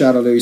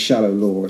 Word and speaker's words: Shadaloo: Sometimes it's pronounced Shadaloo, sometimes it's Shadowlore. It's one of those translation things.